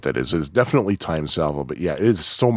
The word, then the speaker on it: T:ME Salvo: And by the way, I did have a question as to how the game is pronounced, because it is spelled T:ME Salvo. I was like, is it T:ME? Is it T:ME? Is it what? But I have heard from Mike himself that it is Time Salvo,